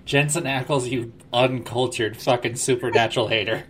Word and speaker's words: Jensen Ackles: No, that's Jason Aikman or whatever his name is. – Jensen Ackles, you fucking... I'm about to go stand just so for Brandon to not be here, so... Mispronounced Jensen Ackles, dare you.